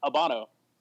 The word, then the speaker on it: Habano: Because the H is silent. It's Habano.